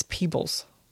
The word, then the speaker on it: Peebles: It's Peebles. But when you say it, like it ends up sounding like the plural of people.